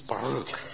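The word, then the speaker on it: Baruch (ברוך): Now, if you're going to say it right, you're going to have to put the little bit of roll on Baruch. It's Baruch. Baruch Hashem Adonai.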